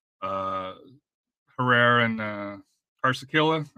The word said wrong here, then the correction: Carrasquilla: Artur, as far as the Corsicilla. Oh, man. Carrasquilla? You're talking about Carrasquilla, right? – Something like that, yeah.